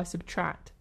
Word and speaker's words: SBTRKT: Let's listen to Wildfire by Subtract. Subtract isn't actually spelled Subtract. It's spelled S-B-T-R-K-T. And I thought they were called... – Indie. – I thought they were called S-B-T-R-K-T.